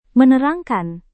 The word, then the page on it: menerangkan: Indonesian